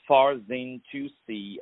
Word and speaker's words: Farzin Toussi: the artistry of Mr. Farzin Toussi.